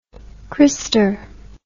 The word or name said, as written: christer